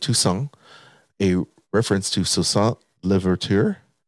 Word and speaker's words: Toussaint: It's not, there's no T. – Toussaint? – You don't pronounce the T in French. – That's right. Toussaint.